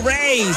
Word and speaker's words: Raye: Esta artista se llama Ray, se escribe Raye.